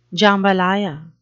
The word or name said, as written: jambalaya